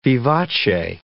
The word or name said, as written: vivace